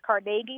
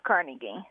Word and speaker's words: Carnegie: So it's only in New York that it's pronounced Carnegie. – Well, mostly. – Everywhere and people who learned it from New Yorkers. – Carnegie. – Yeah. – Yeah, Western Pennsylvania, they get Carnegie right.